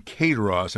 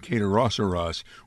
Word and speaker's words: Kayaderosseras: I, I notice you're saying cateros, and I'm saying Kayaderosseras, which is right. – Well, Kayaderosseras is phonetic spelling, but most people say the cateros.